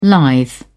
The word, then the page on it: lithe: British English